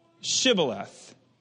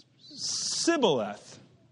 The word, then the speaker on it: Shibboleth: Then say they unto him, say now Shibboleth. And he would say, Sibboleth. No, I said say Shibboleth. Well, that's what I said, Sibboleth. For he could not frame to pronounce it right.